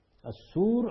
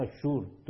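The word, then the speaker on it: آشور: اس میں غالباً بنی اسرائیل کی تاریخ کی ان دو بڑی تباہیوں کی طرف اشارہ کیا گیا ہے جن میں یہ شاہ اشور اور اشور دونوں تلفظ ٹھیک ہے, اور شاہ بابل نبوخذنظر کے ہاتھوں مبتلا ہوئے.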